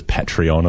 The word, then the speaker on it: Patreon: Some people have been telling me that I'm supposed to pronounce it as Patreon or something. It is spelled P-A-T-R-E-O-N, but I don't care. It's trying to be patron. That's what the word's trying to be. I'm going to say it anyway. I'm sticking with GIF and I'm sticking with patron.